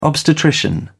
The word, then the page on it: obstetrician: British English